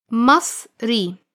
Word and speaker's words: Masri: Masri. And for a female, Masriya. We will always have this E sound at the end for a male and ella for females.